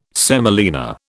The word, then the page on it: semolina: British English